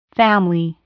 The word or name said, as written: family